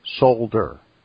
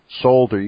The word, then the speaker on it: solder: And he once again corrected us on the pronunciation of solder. Okay. I'm trying to say that. Solder. You got to get the L in there. Solder. Try it.